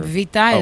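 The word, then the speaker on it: Vita (βήτα): Vita is the letter B. – Beta. It's beta with a B. – Yeah, beta. – Yeah, but vita is how you say it in Greek.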